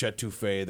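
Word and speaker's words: étouffée: I had a crawfish etouffee that was just outstanding.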